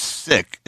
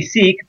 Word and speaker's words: Sikh: It's Sikh. How do you pronounce it? – Yeah, so anglicized, it they say Sikh, but in terms of from the way we pronounce it, it's Sikh. Yeah, S-I-K-H.